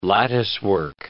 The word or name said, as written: latticework